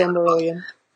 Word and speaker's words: Silmarillion: Silmarillion.